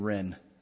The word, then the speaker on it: Wren: Brian Wren, spelled with a W. I guess the W is silent. Brian Wren.